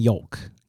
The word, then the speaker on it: yolk: Phlegm, queasy, roaches, secrete, slacks, slurp, smear, squirt, vicious, vomit, and yolk. – Oh my god! – Is it yolk or yolk? – Yolk. – But it's YOLK, not YOLK. – Silent L.